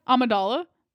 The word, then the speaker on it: Amidala: Amidala? – Amidala.